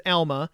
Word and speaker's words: Alma: I understand it's proper to pronounce the school I saw last week as Alma, and at the Britain school is pronounced as Albion.